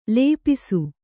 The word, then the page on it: ಲಿಪಿಸು: kannada